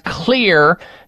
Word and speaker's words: Xlear: Xlear nasal spray with xylitol. Xlear is spelled with an X. X is for xylitol. X L E A R.